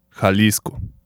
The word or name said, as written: Jalisco